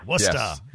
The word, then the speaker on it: Worcester: That's how you say that, right? Worcester? – Well, it's like you trying to pronounce it. – Worcester? – Yeah. – Mo, what's up, man? How are you? – Hi, Mo. – Worcester, they say. Worcester. – Worcester. Yes.